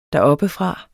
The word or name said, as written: deroppefra